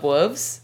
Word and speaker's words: Wuves: I'm going to talk about how much I love Wuves. – Wuves. – Yeah. – Can you spell that for us? – W-U-V-E-S.